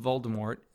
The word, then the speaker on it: Voldemort: confirmed that the T in Voldemort is actually silent, contrary to its pronunciation in the movies.